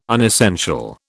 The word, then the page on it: unessential: British English